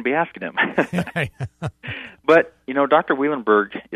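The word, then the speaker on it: Wielenberg: But Eric Weilenberg, and you pronounced it correctly, Weilenberg,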